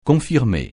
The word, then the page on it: confirmer: francais